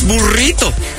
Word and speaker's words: burrito: Well, I'm sorry, that's how you properly pronounce burrito. You know? You just have to pronounce it like that.